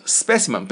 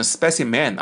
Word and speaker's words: specimen: 最后一个单词 specimen 不要念成 specimen 啊，是 specimen， specimen 是 specimen 什么意思啊？